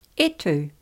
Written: e tu

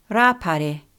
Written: Rapare

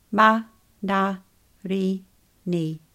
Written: manarini

